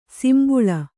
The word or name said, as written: ಸಿಂಬುಳ